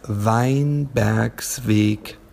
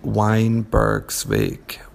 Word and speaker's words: Weinbergsweg: And the address is Weinbergsweg 1A. And the little bit more English pronunciation would probably be Weinbergsweg, which is also fine.